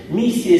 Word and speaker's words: Миссия: Миссия, если ударение на первый слог, то это значит какое-то особое поручение, особая задача, которая выполняется человеком или каким-то обществом, или какой-то группой где-то на выезде.